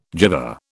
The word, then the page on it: gyver: British English